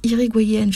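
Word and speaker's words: Hirigoyen: mais en tout cas nommé par Marie-France Irigoyen, qui est spécialiste... – Irigoyenne. Ça se prononce Irigoyenne, je crois.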